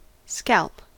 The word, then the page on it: scalp: American English